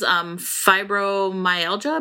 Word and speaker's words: fibromyalgia: because she has fibromyalgia.